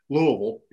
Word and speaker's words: Louisville: You know, it's just Louisville, you know, is how we say it. And then, you know, someone would say Louisville or Louisville, and I'm like, no, it's Louisville, you know, and there's a big, I don't give a shit.